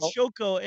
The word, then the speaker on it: Xoco: And Xoco, it's pronounced like that. That would be the Mayan pronunciation.